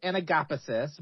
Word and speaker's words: anagapesis: I believe it's pronounced anagapesis, but I have heard it in several different formats. But anagapesis seems to be what I've heard the most.